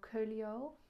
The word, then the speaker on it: Coelho: Eh, van Paulo Coelho spreek je het volgens mij uit. Je schrijft het als Ho met een C. Paulo Coelho, spreek je dus uit.